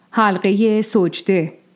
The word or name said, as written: Sajdeh